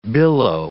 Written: billow

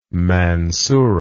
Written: Mansura

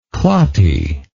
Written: clotty

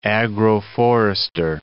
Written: agroforester

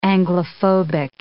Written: Anglophobic

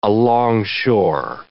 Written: alongshore

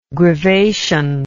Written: grivation

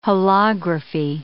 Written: holography

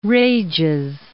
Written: Rages

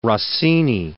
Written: Rossini

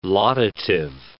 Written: laudative

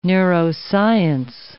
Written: neuroscience

